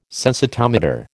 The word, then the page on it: sensitometer: American English